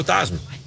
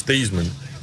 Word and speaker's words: Theismann: Way you pronounce his name is Theismann, but they wanted to do a Heisman campaign for him at Notre Dame, and he became Theismann.